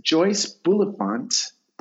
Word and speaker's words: Bulifant: But Mrs. Cross, Lloyd Cross's wife, was played by, oh my gosh, how do you say her name? It is Joyce Bulifant, who most people know for playing Mrs. Davis in the Airplane movie in 1980.